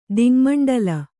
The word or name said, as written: ದಿಙ್ಮಂಡಲ